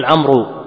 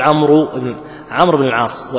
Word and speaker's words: عمرو: لا، من ناحية نحوية، وإلا الواو هذه، إنما الواو هذه التي في عمر ما تنطق. بعض الناس يقول عمرو، هي ما تنطق، عمرو بن العاص، واضح؟ ما تقول عمرو بن العاص، وإنما أثبتت في الزمن الأول لكي يفرق بينها بين عمر وعمر، وإلا ما تنطق.